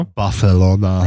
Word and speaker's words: Barcelona: Barcelona.